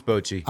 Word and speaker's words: Bochy: Bruce Bochy.